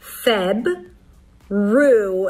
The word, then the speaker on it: February: There are two ways to pronounce this word. The hard way and the easy way. The hard way with an R. Feb. Roo. An R sound and then a tense oo.